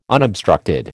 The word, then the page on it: unobstructed: American English